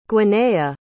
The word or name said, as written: gwernaeh